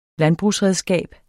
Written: landbrugsredskab

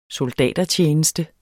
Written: soldatertjeneste

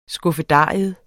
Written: skuffedarium

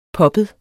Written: poppet

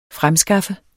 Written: fremskaffe

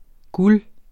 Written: GULERE

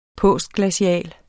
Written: postglacial

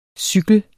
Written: cykel

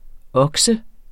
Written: okse

